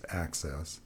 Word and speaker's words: AHCCCS: So our state Medicaid agency, which is ACCESS, the Arizona Healthcare Cost Containment System, the acronym is AHCCCS, which is universally pronounced ACCESS,